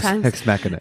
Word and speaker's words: deus ex machina: how do you say it in Latin? The deus machinus sometimes. – Deus ex machina.